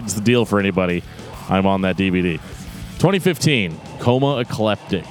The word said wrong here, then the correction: Coma Ecliptic: No, it's Coma Ecliptic, not Coma Ecleptic.